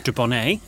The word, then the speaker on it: Dubonnet: And then we have a bottle of dubonnet. – Dubonnet. – Dubonnet.